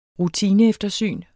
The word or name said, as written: rutineeftersyn